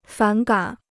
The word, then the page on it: 反感: fǎn gǎn